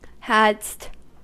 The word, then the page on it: hadst: American English